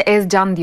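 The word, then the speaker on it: Ezgjan: Bu arada Alioski'nin adı Ezcan diye okunuyor. Onu da söyleyeyim. – Ezcan. – Aynen değişik yazılıyor.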